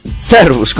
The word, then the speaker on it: Servus: Es Servus. Servus, con acento en la ser.